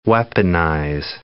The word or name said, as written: weaponize